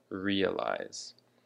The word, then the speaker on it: realize: And the emphasis of this word is on the first syllable, realize, which brings us to the next word, realization.